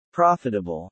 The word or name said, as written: profitable